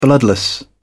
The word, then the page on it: bloodless: British English